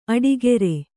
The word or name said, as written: ಅಡಿಗಾರ